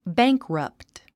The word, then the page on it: bankrupt: bǽŋkrʌpt; バァンクラァプト